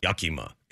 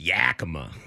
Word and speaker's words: Yakima: You know, like it's not Yakima, it's Yakima.